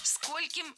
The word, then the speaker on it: скольким: В слове «сколько» во всех формах ударение ставим на первый слог. Скольким, сколькими, скольких.